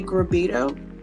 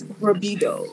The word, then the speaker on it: Grabito: Well, I'm gonna give you my way I say it. I always just say Grabito. And the funny thing about it is that I've heard Grabito, I've heard Rub, I've heard Grubdo. So I've heard different variations of everyone trying to pronounce it, but it's Grabito.